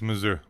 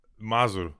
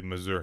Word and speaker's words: Mazur: It's Mazur. Well, wait a minute. It's been such a long time since I've said it properly. Mazur. That's the proper accent. – Mazur. Right. Okay. – You know, I just say Mazur because I've lived here for so long that. Yeah, I've just gotten used to saying my own name wrong.